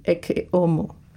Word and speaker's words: Ecce Homo: But in Latin, or in my version of Latin, which would come from like a Spanish vein, it would be Ecce Homo. You wouldn't pronounce the H.